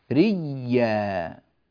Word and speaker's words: ريا: أدغمت الياء الأولى في الياء الثانية فصار النطق النهائي لها ريا.